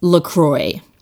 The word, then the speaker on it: LaCroix: And it is LaCroix. The website says, you know, damn the French. It's not the French pronunciation. It rhymes with boy.